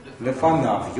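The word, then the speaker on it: Лефанав (לפניו): Лефанав. Йод там не произносится. Он пишется, но не произносится.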